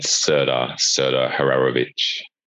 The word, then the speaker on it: Serdar Hararovich: It's Serdar Hararovich.